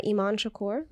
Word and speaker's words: Emon Shakoor: I also go by Emon Shakoor, like how you pronounced my name so graciously.